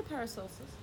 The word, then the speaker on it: Paracelsus: The term was coined by Paracelsus. I'm not saying that wrong. – No, I'll say Paracelsus.